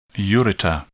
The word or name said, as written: ureter